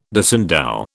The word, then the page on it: disendow: British English